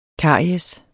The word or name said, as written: caries